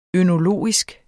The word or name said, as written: ønologisk